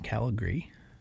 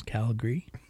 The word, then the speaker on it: Calgary: But I saw that he's playing in Calgary. See how I did that? – Yeah, nice. – Two syllables, Calgary. He's playing with Skinny Dick, D-Y-C-K.